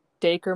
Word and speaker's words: Dacre: so you Dacre, should watch it. Huh? – Dacre Montgomery.